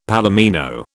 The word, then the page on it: palomino: British English